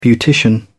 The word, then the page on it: beautician: British English